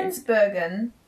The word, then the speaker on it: Spannungsbogen: Spannungsbogen. Spannungsbogen. Genitive Spannungsbogen. Plural. Spannungsbogen. Or Spannungsbogen. – Thank you. Great. Good pronunciation.